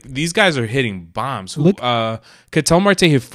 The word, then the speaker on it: Ketel: How do you say his name? Ketel or something? – Ketel Marte, yeah.